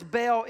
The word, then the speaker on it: Baal: The word is Baal. It is pronounced as we spell the word Baal, B-E-L-L. But in the Bible, it's the gods of Baal, B-A-A-L.